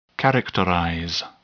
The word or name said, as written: characterize